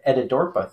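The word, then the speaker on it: Etidorhpa: You brought up the Etta Dorpa thing, which hopefully I'm pronouncing that correctly too. – No, you totally pronounce it correctly.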